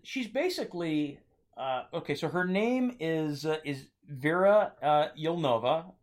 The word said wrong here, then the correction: Yelnikov: Yelnikov. Excuse me, Yelnikov. – Vera Yelnikov. Her name is Vera Yelnikov.